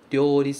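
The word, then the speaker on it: ryori (料理): Cuidados com esta palavra, ryori, na sua pronúncia. Primeiro, ou significa o. A gente pronuncia o. Então, ryori, ryori. Outro cuidado é esse começo, ryo, ryo, ryo. É de uma vez só. Cuidado para não transformar em ryo.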